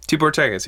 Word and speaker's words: Tibor Takacs: Now, Tibor Takacs. Am I saying his name right? – Tibor Takacs, yeah.